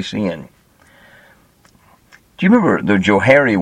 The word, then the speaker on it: Johari: One of the guys' first name was Joe, and the other guy's first name was Harry, so they just called it the Johari window. They spell it J-O-H-A-R-I, so it looks kind of weird, but it's interesting.